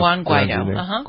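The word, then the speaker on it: Guaidó: The opposition leader there, Juan Guaido, is that how we decided we were pronounced? – Juan Guaido, Guaido, has officially requested a meeting in his southern command, the delegation.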